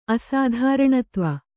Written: ಅಸಾಧಾರಣತ್ವ

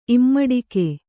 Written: ಇಮ್ಮಡಿಕೆ

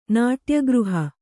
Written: ನಾಟ್ಯಗೃಹ

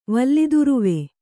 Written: ವಲ್ಲಿದುರುವೆ

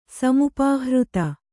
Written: ಸಮುಪಾಹೃತ

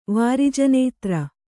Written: ವಾರಿಜನೇತ್ರೆ

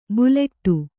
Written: ಬುಲೆಟ್ಟು

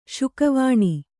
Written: ಶುಕವಾಣಿ

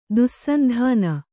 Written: ದುಸ್ಸಂಧಾನ

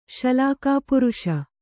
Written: ಶಲಾಕಾಪುರುಷ

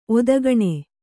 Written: ಒದಗಣೆ

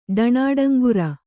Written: ಢಾಣಾಡಂಗುರ

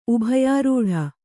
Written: ಉಭಯಾರೂಢ

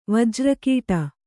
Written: ವಜ್ರಕೀಟ

